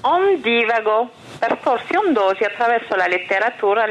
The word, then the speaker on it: Ondivago: Benvenuti ad Un Divago. Un Divago, con l'accento sulla I,